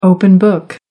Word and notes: open-book: American English

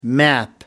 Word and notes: map: American English

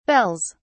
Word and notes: bells: British English

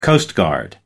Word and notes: coastguard: American English

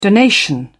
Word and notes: donation: British English

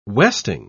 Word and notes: westing: British English

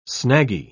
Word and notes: snaggy: British English

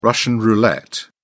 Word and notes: russian roulette: British English

